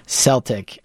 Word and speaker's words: Celtic: But in general, this author who grew up in the west of Ireland notes that Celtic is the much more common. – Is that right? – Yeah, because he goes... nearly every other English word beginning with CE has a soft C sound. Cedar, ceiling, cell, cement, scent, cereal, certain, cesspit, and so on and so forth.